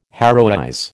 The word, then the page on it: heroize: American English